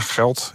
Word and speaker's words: Gelt: Ja, Geld. Ja. G-E-L-T. – G-E-L-T, ja.